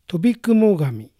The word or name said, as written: Tobikumogami